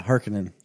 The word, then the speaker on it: Harkonnen: Baron Harkonnen? – Baron Zemo? – No. – Yeah, Harkonnen.